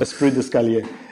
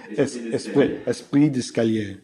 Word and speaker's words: esprit d'escalier: Esprit d'escalier. Esprit d'escalier.